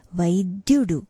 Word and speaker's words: vaidyudu: Vaidyudu. Dya, the cluster there is smooth, but it has a slight emphasis.